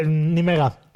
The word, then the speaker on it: Nimega: Se escribe ¿cómo se dice? – Nimega. Ya, es holandés. – Se escribe Nijmegen, ¿vale? – Nijmegen, Nimega, sí.